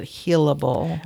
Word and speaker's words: heallabel: I think she pronounces it healable.